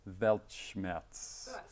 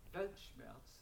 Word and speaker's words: Weltschmerz: and I can never pronounce Weltschmerz. – Brilliant. – Awesome. – You've done it. – And I got it wrong then. – And your understanding of the meaning? – Weltschmerz, yeah.